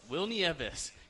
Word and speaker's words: Nieves: Does his name rhyme with Jill Gievis? – It does. Will Nieves caught him in his very first major league game in 2002 against the Yankees at Qualcomm Stadium.